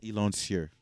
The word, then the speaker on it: Elancieaux: No, X is silent. The X is silent, people. – It's Yulena? – It's Elancier.